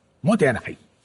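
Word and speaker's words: mottainai: Motainai. – Hoy perfecto. Cuéntanos qué es el Motainai em, y cómo puede ayudarnos a, mm. mejorar en nuestras vidas. – Eh, motainai tiene que pronunciarse como enfadado. Motainai.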